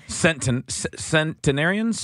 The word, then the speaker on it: centenarians: The number of U.S. centenarians. Am I saying that right? I'm sorry if I'm not. – 100-year-olds? – Yeah. – I think so. – Centenarians? – Centenarians. – Okay.